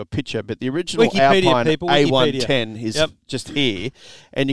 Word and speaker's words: Alpine: Not Alpine. It's spelt Alpine, but it's an Alpine A110.